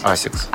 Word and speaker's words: ASICS: Я попробовала разные, там, Asics, Mizuno, или Asics, как правильно говорить? – Asics. – Asics? Asics.